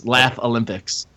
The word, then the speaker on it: Laff-A-Lympics: It's spelled LAFF-A-LYMPICS. Laugh Olympics.